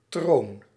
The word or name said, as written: throne